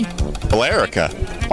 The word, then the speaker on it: Billerica: How do you pronounce it? Billerica. There's an E in there. – Billerica. – Billerica, maybe? – Billerica. – Oh, that makes sense. Just leave out the E.